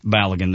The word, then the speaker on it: Balogun: I don't know how to say his name, was it? – Florian Balogun. – Balogun, there you go.